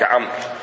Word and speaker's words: عمرو: فالعرب وضعوا واوا، أو العلماء أو النحاة واوا لعمر حتى يميزونها عن عمر، فصار هذا عمر وذاك عمرو، فيها واو. الواو لا تلفظ، فقط للتفريق بينها وبين عمر، حتى يقولون، حتى تحفظها، يقول استعارها عمرو من داود.